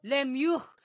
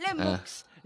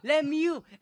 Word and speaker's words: Lemieux: Aye, and by the way, it's pronounced Mario Lemieux, not Lemux, Lemieux, and it's Hugo Wah, not Roy, Wah.